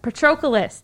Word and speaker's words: Patroclus: I am not going to say his name correctly. Oh, my God. Patroclus. There we go. That's how you say it.